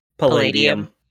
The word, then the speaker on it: Palladium: Okay, first, before I say anything, how do you say that metal name again? – Palladium. – Palladium. Palladium.